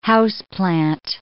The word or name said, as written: houseplant